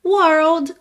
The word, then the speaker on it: world: Mas lembra, primeiro faz o R da porta com a perna esquerda. Então, were, depois você põe o L no céu da boca. World, world.